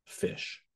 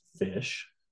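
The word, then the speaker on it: ghoti: This word is actually pronounced fish. – What? – Yeah, go to the next slide. Actually pronounced fish. Okay, you go to the next one. – Yep. – And how do we know it's pronounced fish? Well, because it's, you know, has a GH, which is like in rough. – Yeah. – Okay. And O, like in women.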